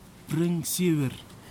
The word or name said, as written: pringsiwir